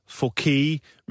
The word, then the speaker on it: Fuck: Det udtales så bare Fouquet, men øh, hvis man tjekker ham på Facebook, så hedder han altså fuck.